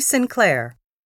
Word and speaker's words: Sinclair: Judy Sinclair. – Sinclair. How do you spell that, please? – S-I-N-C-L-A-I-R.